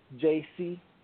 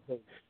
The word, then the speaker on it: Jayce: The name Jace is J-A-Y-C-E. Some people say, you know, J-C, but it's pronounced Jace. Yeah, it's pronounced Jace.